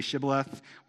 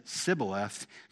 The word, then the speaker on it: Shibboleth: When they said no, they said to him, then say Shibboleth. When they said Sibboleth, for they could not pronounce it right, they seized him and slew him at the fords of the Jordan, and there fell at that time 42,000 Ephraimites.